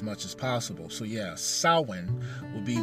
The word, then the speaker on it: Samhain: The correct way, to say what looks to be is the word Samhain is actually Samhain. It's an Irish word. And so it's not pronounced the way it looks.